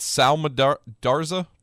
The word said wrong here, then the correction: Samardzija: the pitcher? – You mean Samardzija? – Samardzija? – Yeah, Samardzija. He was a former tight end for Notre Dame.